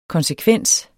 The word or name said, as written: konsekvens